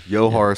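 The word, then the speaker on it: Dzhokhar: Pronounce it because I'll butcher it. Sarnayev. – Yohar Sarnayev.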